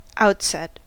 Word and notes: outset: American English

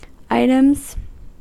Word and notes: items: American English

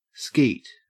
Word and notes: skeet: Australian English

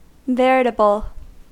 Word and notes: veritable: American English

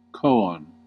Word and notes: koan: American English

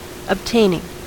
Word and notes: obtaining: American English

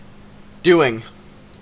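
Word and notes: doing: Canadian English

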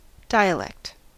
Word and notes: dialect: American English

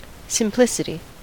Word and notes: simplicity: American English

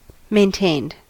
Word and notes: maintained: American English

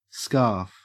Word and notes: scarf: Australian English

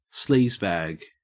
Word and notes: sleazebag: Australian English

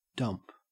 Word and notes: dump: Australian English

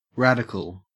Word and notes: radical: Australian English

